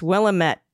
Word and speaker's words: Willamette: The way we remember how to say it correctly, it's Willamette, damn it. It's Willamette, damn it. Ah, I got it. Like, damn it, Janet. Willamette.